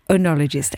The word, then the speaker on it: oenologist: You're a consultant oenologist. Oenologist. Am I saying that correctly? – Yeah, oenologist. Correct.